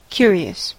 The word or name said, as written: curious